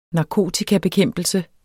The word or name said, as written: narkotikabekæmpelse